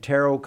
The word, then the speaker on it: tarot: And tarot cards, they pronounce it, the T is silent.